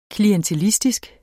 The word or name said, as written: klientelistisk